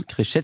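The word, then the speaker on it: Créchets: Créchet, pardonnez-moi, oui, on prononce comme ça. On prononce toutes les lettres.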